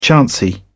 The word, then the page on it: chancy: British English